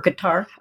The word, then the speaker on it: Qatar: The problem is that it's actually three Arabic letters that don't exist in English. So it's in Arabic, it's Qatar, but people say Qatar, Qatar, whichever you'd like. I usually say Qatar. – Yeah, I had a CIA professor retired, and he pronounced it gutter. – Yes, well, again, a little nuance of Arabic dialects, the Gulf dialect, it pronounces it as a G.